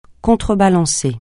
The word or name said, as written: contrebalancer